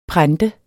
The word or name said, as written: prente